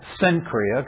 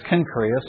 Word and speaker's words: Cenchrea: Now, when I read that name in my Bible, I like to say Cenchrea, because that's how it looks like it's spelled, right? But actually in the Greek, it's spelled with the first letter, the kappa, which is obviously the k sound in Greek. So the really, apparently the proper pronunciation of the name is Cenchrea.